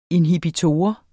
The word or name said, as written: Inhibitor